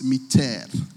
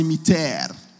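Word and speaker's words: mittere: Now, the other part, the mit part, is actually from the Latin word, which is pronounced miter. Can everyone say miter? Miter. That's how they say in Latin.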